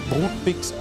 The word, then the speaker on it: Brutbix: So spricht man im Untertaunus tatsächlich und es ist vollkommen richtig, der ist die Brotdose, die wie heißt es, Brutbix, also Brotbox.